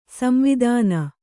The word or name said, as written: ಸಂವೇದನ